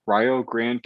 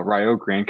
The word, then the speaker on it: Rio Grande: He played for Division II Rio Grande College in Ohio. And yes, I pronounced that right. Rio Grande College in Ohio.